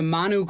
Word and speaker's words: Manu: Now to Peru, to the, how do you pronounce it, Manu Cloud Forest, you guys? – Yeah, it's the Manu Cloud Forest.